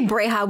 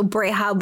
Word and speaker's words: Breha: I would say Breha with an H. So Breha, but like a soft H.